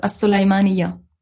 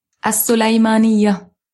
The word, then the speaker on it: As-Sulaymaniyah: We would fly directly into that area, into, I think they pronounce it Sulaymaniyah. That's, again, yeah. It's my terrible Arabic pronunciation. – The official pronunciation is As-Sulaymaniyah. – Say that again. – As-Sulaymaniyah.